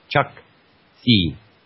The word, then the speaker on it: Chukchi: How do they pronounce that? Chukchi. She said.